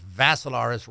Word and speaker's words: Vassilaris: They have different, you know, last names and all that, but mine is spelled with three I's, D-I-M-I-T-R-I. And the last name is Vassilaris, rhymes with Assilaris, but you add a V to it.